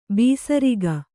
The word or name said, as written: ಬೇಸರಿಕೆ